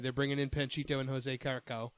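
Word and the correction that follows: José Carioca: They are doing, or Carcoa, I forget how to say it. – Jose Carioca. – Yeah.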